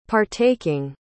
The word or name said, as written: partaking